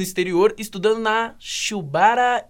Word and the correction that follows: Shibaura: Institute of Technology, foi isso? Isso. Fala para mim direitinho como é que fala. – Uh, Shibaura Institute of Technology.